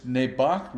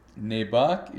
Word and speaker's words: Nebaj: Nebac region, where we spent a couple of weeks, it's spelled Nebaj, and it's in the far north of Guatemala.